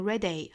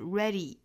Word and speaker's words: ready: You're ready， you're ready，不要读成 ready 啊，是 ready，最后尾部是一个长元音 e 的短板 e， ready。